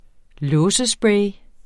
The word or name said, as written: låsespray